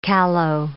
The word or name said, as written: callow